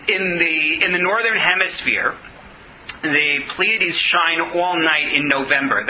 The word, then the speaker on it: Pleiades: First of all, I hope I'm pronouncing Pleiades right. It sounds right to me. Yes, Shana? Okay.